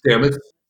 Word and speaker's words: Stamets: Stamets.